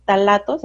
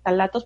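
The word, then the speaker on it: ftalatos: unas situaciones tóxicas que se llaman talatos, así se escribe con F, talatos, porque quieren investigar.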